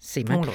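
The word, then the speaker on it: Szeman: Er det rigtigt udtalt? – Ja, Seemann.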